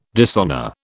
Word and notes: dishonour: British English

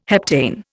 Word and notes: heptane: Indian

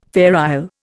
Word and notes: Fair Isle: Indian

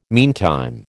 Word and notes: meantime: American English

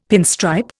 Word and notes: pinstripe: Indian

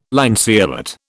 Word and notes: lanceolate: British English

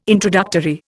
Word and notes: introductory: Indian

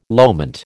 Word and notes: loment: American English

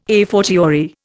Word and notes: a fortiori: Indian

